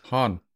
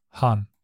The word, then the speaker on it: Hahn: Oder Hane, wie, wie, wie würde man Hahn auf Englisch aussprechen? Hahn. Hahn, ja.